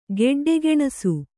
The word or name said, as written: ಗೆಡ್ಡೆಗೆಣಸು